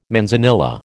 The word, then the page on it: manzanilla: American English